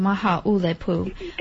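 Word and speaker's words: Maha ulepu (Māhāʻulepu): Maha ulepu. – It's maha ulepu with the accent on the U.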